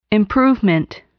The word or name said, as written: improvement